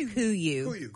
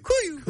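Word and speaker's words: KUIU: It's pronounced Kuyu. – Kuyu. – Kuyu.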